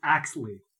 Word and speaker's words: Axley: It's Axley. – Axley. My bad. I didn't. The silent L threw me off. – Silent L. – It's kind of like Ashley, but I thought we were speaking in Dwarven.